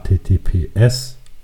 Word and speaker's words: HTTPS: Generell natürlich, das wissen wahrscheinlich auch schon viele, ähm, immer auf die Verschlüsselung achten, die SSL-Verschlüsselung, also oben äh, HTTPS, da liegt die Betonung auf das S und ähm, wird auch oft mit einem Schlosssymbol symbolisiert.